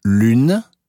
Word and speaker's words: lune: Ü wie in Lune wird ausgesprochen wie Ü in Büro.